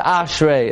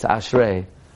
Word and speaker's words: Ashrei: It's not Ashrei, it's Ashrei.